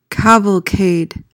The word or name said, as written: cavalcade